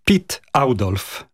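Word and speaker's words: Oudolf: Jego pierwsze imię to jest Pit, a nazwisko Audolf. To dla nas nie jest takie naturalne przeciągnąć to, ale tak się poprawnie wymawia po holendersku. – Czyli Pit Audolf.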